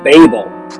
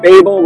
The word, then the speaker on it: Babel: Okay, so we had a Tower of Babel last time, and if you remember, they decided to build a big city with its Tower of Babel, not Abel. Babel rhymes with Abel.